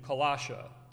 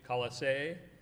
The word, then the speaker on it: Colossae: I grew up saying Colossia, the town, but I guess it is technically, David, Colossae.